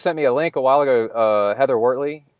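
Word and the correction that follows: Wurtele: and her... – Heather Wortel. – Wortel. Is it Wortel? Am I been saying it wrong? – Oh, it's okay.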